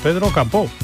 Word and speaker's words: Pedro Capó: Es un artista que se llama Pedro Capó, con acento en la O, Pedro Capó.